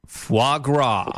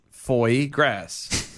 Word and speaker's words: foie gras: It says foie gras. – Foie gras. – Foie gras. The E is silent.